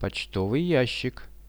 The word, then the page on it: pochtovyi jashik: Russian